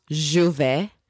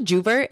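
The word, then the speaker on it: J'ouvert: But basically, it's a Caribbean festival, and it's pronounced Juve, spelt Juvert. But it's not pronounced like that. The amount of people who are like, you're going to Juvert?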